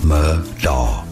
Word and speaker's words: Murdaugh: Well, and so it is spelled Murdaugh, but Amy told me, hey, idiot, it's Murdaugh.